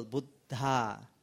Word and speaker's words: Buddha: Buddhana sasanang. Now buddhana, the B, there is a double D, right? So, Buddha. D-H-A. The H is also audible. Buddha.